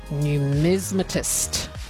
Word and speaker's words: numismatist: So Deb on the text line correcting my pronunciation on numismatist. There we go. It's an extra syllable. I was just missing that out.